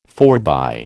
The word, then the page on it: forbye: American English